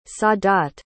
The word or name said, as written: sadat